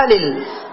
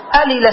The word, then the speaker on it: ألل: مثل إيش مثل ألل ألل السقاء إذا تغيرت رائحته ألل السقاء فحق أن يقال ألا مثل ملة أصلا ألل هي مثل ملة تماما مللة ولكن العرب فكوها قالوا ألل.